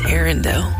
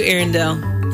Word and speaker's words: Arendelle: I think it's Arendelle. That's the second one, right? That Correct.